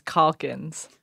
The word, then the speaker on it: Calkins: I don't think it rhymes with chalk. – It does. It is Calkins. – Calk and chalk. – It comes up every time. – Cock. – Like I'm going to caulk a tub.